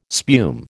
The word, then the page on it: spume: American English